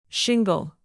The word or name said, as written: shingle